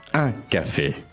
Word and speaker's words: un café: So how do you say a café? – Un café. – So let's work on the pronunciation of that. – Mm-hmm. Un. – Un. – Mm-hmm.